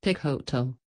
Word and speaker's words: Picciotto: With Guy Puccietto. How do you pronounce his last name? – Oh, you very dumb boys. Let Factbot help you. His name is pronounced Guy Picciotto.